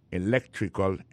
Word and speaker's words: electrical: Electrical engineering. Ça veut dire accent tombé dans electrical là, c'est sur deuxième syllabe là. Electrical engineering. C'est sur deuxième syllabe là. Elect là, plus appuyé. Electrical engineering.